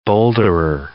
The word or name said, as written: boulderer